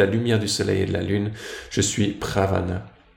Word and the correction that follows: pranava: pranava, pardon, la syllabe aum dans tous les Vedas.